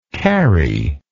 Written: carrie

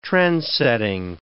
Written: trendsetting

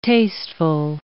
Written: tasteful

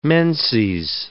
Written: menses

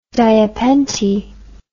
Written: diapente

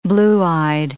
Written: blue-eyed

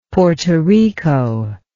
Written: Porto Rico

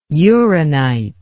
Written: uranite

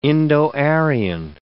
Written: Indo-Aryan

